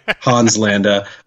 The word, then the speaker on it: Landa: La- Landa, mm-hmm. excuse me, I was trying to do all long A's there. Hans Landa uh,